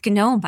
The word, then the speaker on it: GNOME: It's GNOME, by the way. I mean, you can pronounce it however you want, but the G is for the GNU, which is traditionally for freedom.